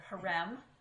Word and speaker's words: harem: But and I'm going to say the Arabic word. This is how they would pronounce it. Harem. Anybody ever heard of the word harem in Arabic? – Yeah.